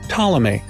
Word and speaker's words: Ptolemy: His name is actually pronounced Ptolemy. Apparently, nobody knows where his P went.